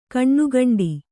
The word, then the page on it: ಕಣ್ಣುಗಂಡಿ: kaṇṇugaṇḍi